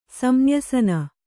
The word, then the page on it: ಸಂನ್ಯಾಸಿನಿ: samnyāsini; san nyāsini